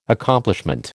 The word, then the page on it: Accomplishment: American English